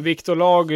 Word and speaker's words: Victor Laag: Men Viktor Lag, eh, eller Lag med två A, jag hur du nu vill uttala det. Varmt välkommen till podden och eh, vi kan väl börja med, med frågan, vem är du då? – Ja, tack så jättemycket. Eh, Viktor Lag, det är bra.